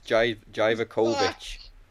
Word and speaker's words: Dijakovic: I say it right. It's Dijakovic.